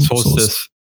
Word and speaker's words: Solstice: Ähm, aber ich, ich, bin auch gerade jetzt äh, kurz davor, das Leuchten für die Solstice oder wie wird das ausgesprochen? – Solstice.